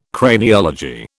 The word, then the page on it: Craniology: British English